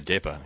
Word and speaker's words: depa: Well, we're getting towards the end, but you, in your final chapter, you bring up this concept, this Tibetan word, and tell me if I'm saying it wrong, depa. – Yeah, that's right, depa. Yeah, depa.